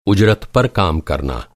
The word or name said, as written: उजरत